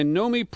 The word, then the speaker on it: Nomi: We're joined now by Nomi Prinz, who returns to our program. Her name is Nomi, N-O-M-I, like Naomi without the A, Prinz, P-R-I-N-S, and I spell it because I really want you to read her book.